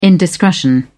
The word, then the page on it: indiscretion: British English